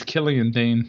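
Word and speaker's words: Killian Dain: And eventually, Mojo eliminated Killian or Killian? – I think it's Killian Dane. – Killian Dane. There you go. I don't know why I keep mispronouncing that.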